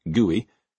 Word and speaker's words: GUI: Compared to the black screen with eerie green characters that had preceded it, this graphical user interface, or GUI, pronounced GUI, as it came to be known, represented at least as radical a break as when silent movies shifted to talkies.